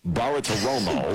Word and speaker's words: Bartiromo: Bartiromo.